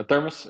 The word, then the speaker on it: thermos: I bought myself a, I don't know, thermo, how you say it in English? Thermo. – A thermos, yeah, just a thermos where to keep coffee. – Okay, thermos. Nice, Yeah.